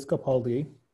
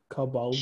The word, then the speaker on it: Capaldi: The next performance was Louis Capaldi. And was it just me or the, what's her name? Taraji, did she say Cabaldi? – She said it wrong. She said Cabaldi.